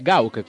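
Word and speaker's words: Gauck: No, è Gauk questo. Gauche è francese. – Infatti, polverizzata da Hollande, quindi mi sa che la Francia c'entra. – Quella... Ah, quella Gauk. Allora si legge Gauk.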